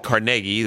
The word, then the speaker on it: Carnegie: You definitely pronounce it Carnegie, the emphasis on the second syllable.